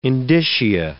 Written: indicia

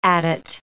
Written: adit

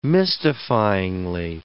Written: mystifyingly

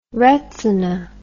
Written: retzina

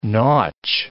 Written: nautch

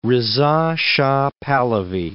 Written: Reza Shah Pahlavi

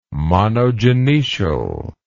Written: monogynoecial

